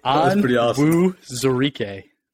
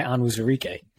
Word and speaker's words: Onwuzurike: Levi Onwuzurike. I think I'm close, Ray. How did I do? – That was sick, dude.